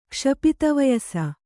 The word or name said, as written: ಕ್ಷಪಿತವಯಸ